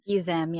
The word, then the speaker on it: Gizem: Like, okay, listen, we're clearly turning Gizem. Is that how you say her name? – Gizem, yeah.